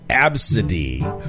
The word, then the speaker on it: ABCDE: Absidy is spelled A-B-C-D-E. That's how the child, so it's A-B-C-D-E. That's her name.